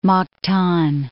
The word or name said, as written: Mactan